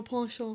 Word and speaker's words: penchant: I may have a ponchance for older women. – A ponchance. – Ponchance. – A ponchance. – Ponchance. Is that not how you pronounce it? Ponchance. I may have a penchant.